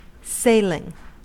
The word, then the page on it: sailing: American English